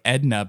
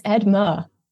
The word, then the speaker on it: Edma: I, I want to say like Edna, but it's not. – It's Edma.